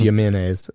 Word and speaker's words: Jimenez: Rampart crash officer Brian Hewitt brought Ismael Jimenez. Jimenez? Did I say that right? It sounds like I'm saying mayonnaise, but I'm sure it's Jimenez.